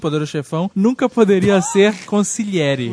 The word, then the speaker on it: consigliere: Assim, no mesmo nível do sotocapo, tem o consigliere. Que é uma palavra tão simples, mas que ninguém consegue falar. – Consigliere. – Mas, na verdade, eu acho que nem tem esse G, né? Na pronúncia.